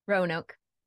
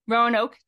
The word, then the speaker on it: Roanoke: Roanoke. – Roanoke, Texas, with her husband, Matt, three children, and a growing herd of animals.